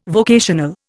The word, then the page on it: vocational: Indian